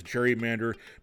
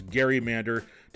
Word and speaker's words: gerrymander: Now, some people pronounce that last word as gerrymander, but the cool redistricting kids know to pronounce it as gerrymander to be congruent with the last name of the former Massachusetts governor and vice president.